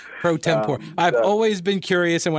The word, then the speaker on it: pro tempore: Is it pro tempore or pro tempore? – It's pro tempore.